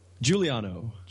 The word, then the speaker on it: Giuliano: It looks like Giuliano. – Giuliano. Okay, okay. – It's kind of like Rudy Giuliani.